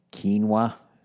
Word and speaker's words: quinoa: And of course, for lunch, I've already made my quinoa. So I learned how that's how you say it, with some black beans, maybe a little salsa.